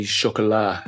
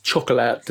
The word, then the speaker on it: chocolat: I'd heard French people, particularly French women, say Chocolat. Mm. And it just lodged in my memory forever. – That's how you say it. – That is the definitive way to say Chocolat. – Yes, It's really onomatopoeic for the melt. – Yes. – Whereas Chocolat, the Anglo-Saxon version, is the snap.